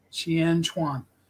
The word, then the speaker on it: Shian Chuan: Shian Chuan.